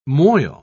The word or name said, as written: moil